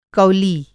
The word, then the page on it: कौली: Hindi